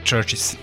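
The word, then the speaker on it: CHVRCHES: A da bi bilo lakše da ih pronađete prilikom internet pretrage, odlučili su da slovo U zamene slovom V, ali se u izgovoru ništa ne menja, dakle Churches.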